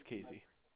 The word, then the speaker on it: Casey: Yep, my name is Casey. – Casey, can you spell it K-A-Z-E-Y? – C-A-S-E-Y. – Can you repeat that? – C-A-S-E-Y.